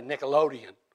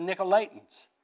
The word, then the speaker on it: Nicolaitans: So then who are the, I hope I'm pronouncing this right, I keep wanting to say Nickelodeon, but I know it's not. I guess that's from too much kid TV, I don't know. Nickelodeons. Is that right? Okay. Okay, you got it. Pronounce it for me. Well, anyway, to top it all off, they tolerated the teachings of the Nicolaitans.